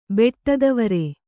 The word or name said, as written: ಬೆಟ್ಟತಾವರೆ